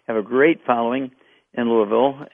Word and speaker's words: Louisville: Actually, you get to Louisville. You have to say it correctly. Louisville.